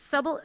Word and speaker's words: subtle: Kurt pining is fine, and I liked it in the first part of the season because it was subtle, subtle enough. It's a silent B in the word subtle.